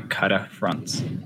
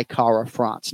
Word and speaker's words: Kara-France: So every day of the week, Kai says his last name with that rolled R. I've never heard him say Kai Kara-France. Now, we're in the business of getting the right syllable right. But those who choose to roll the R can, or those who are able to roll the R can.